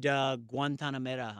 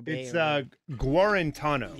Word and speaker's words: Guarantano: Tennessee has 2 pick sixes, or I think 2 pick sixes by the quarterback of Tennessee, Jared Guantanamera. I'm just saying that. I don't know how to say his last name. Guantanamo. – Guarantano.